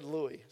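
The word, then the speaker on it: Rui: It's R-U-I, however you want to treat that. They said Louie, so okay, whatever.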